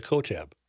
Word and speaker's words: Kotb: K-O-T-B. – Hoda Kotb. – K-O-T-B.